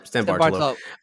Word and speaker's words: San Bartolo: How do you say it again? – San Bartolo.